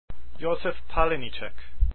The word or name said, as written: palenicek